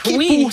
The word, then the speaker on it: Quipu: Et si vous pensiez que c'était déjà vertigineux d'imaginer l'Aniakea, attendez qu'on vous parle de, comment, Kipu? – Kipu, ça s'écrit Kipu. Q-U-I-P-U.